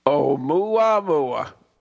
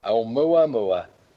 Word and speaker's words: Oumuamua: Umuamua. huh? Umuamua. Is that how you say it? – Yeah. – It means uh, scout. it means yeah, it's called Umuamua. A uh, scout in, in Hawaiian. Yeah. Oumuamua. Oumuamua.